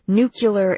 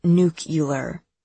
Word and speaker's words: nuclear: Like a nuclear explosion is her exact phrasing, pronouncing it nuke-ular, the way George W. Bush used to say it, even though he knew it was wrong just to piss people off.